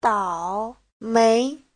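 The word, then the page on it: daomei: dao3mei2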